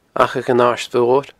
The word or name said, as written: Achadh an Aird Mhoir